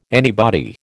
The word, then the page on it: anybody: American English